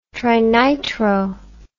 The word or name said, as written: trinitro